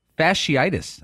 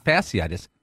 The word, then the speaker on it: fasciitis: Now, Tiger Woods is out of the Hero Open or some big event with a uh, plantar fasciitis. I'm, I'm told it's pronounced not fasciitis, fasciitis.